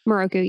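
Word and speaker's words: Miroku: Moroku, is that how you say his name? Moroku? – Moroku, yeah.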